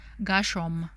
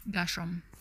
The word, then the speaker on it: Gershom: I think it will be Gershon. Am I saying that right? Gershon? Gershon? Or is it Gershom? Let me scroll down. Oh, it's an M. Gershom. I'm saying Gershon with an N. Gershom. Gershom. Gershom.